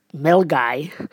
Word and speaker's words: Milngavie: We took the train from Edinburgh to Milngai, which is not spelt like it sounds. It's actually spelt Milngavie, and it's pronounced Melgai.